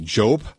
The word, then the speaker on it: Joep: I want to make sure I get his name right. J-O-E-P, Jope, Jope Lang.